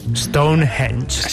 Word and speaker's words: Stonehenge: Eres la persona que mejor he escuchado pronunciar Stonehenge. – Stonehenge.